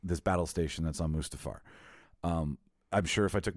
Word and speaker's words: Mustafar: And we were on, I always mispronounce it, so Martin, Darth Vader's planet, Mustafar. – Mustafar.